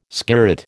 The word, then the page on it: skirret: American English